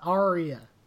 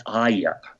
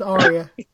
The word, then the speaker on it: Arya: secret, and um, and then and he he makes um, Arya and and Sansa or Aya, however you pronounce it. I, I, I, Arya. – Well, you know, everyone's like Aya. – I don't no, know. No, they're not. It's Arya.